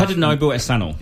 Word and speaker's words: Tadanobu Asano: I'm going to say his name wrong. Tadanasu. – I know the actor I'm talking about. – He's in a lot of great stuff. Tadanobu Asano. – Yeah. That's him. Yes.